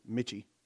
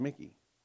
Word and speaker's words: Michie: It's spelled M-I-C-H-I-E, Mitchie. But it's called Mickey.